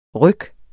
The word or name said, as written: RYKKENE